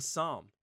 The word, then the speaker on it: Psalm: So it's Saint and Psalm. You know, like P-S-A-L-M. I said that right, right? Like that's Psalm.